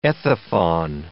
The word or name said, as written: ethephon